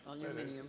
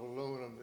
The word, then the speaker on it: aluminium: I'm not quite clear how the English pronounce that, but that's aluminium. – It's a form of aluminum that stands up to salt water.